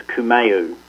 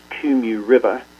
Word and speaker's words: Kumeu: Some of your listeners may read that and mispronounce it as Kumeu, but it's pronounced Kumu River.